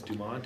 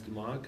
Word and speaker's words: Dumont: Well, I always tell everybody if you ask my grandma, my great grandma, it's Dumont, Dennis Dumont, because we're French and Italian.